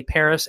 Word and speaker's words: Perez: Give me a follow at It's Ray Perez, ItsRayPerez. If you're wondering why am I pronouncing Perez like Paris, is because that's how they pronounce it on Guam. But It's Ray Perez, and that's on Instagram as well.